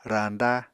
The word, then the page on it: rañda: Apatani